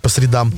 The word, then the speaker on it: средам: Вот приходит Гульнас Миниханова здесь по средам, правильно говорить, по средам.